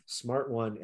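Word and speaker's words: SMRT-1: But they don't have Smart One anymore. Smart One actually is spelled S-M-R-T, no A, S-M-R-T-1.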